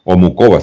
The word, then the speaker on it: Muchová: Esta chiquilla italiana, pues lamentablemente perdió con Carolina Muchova, Muchova se pronuncia, que pues también ha venido jugando un muy, muy buen certamen.